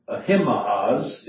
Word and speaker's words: Ahimaaz: And so, verse 19, then said Ahimaaz, is the way it would be pronounced closest to the Hebrew, the son of Zadok.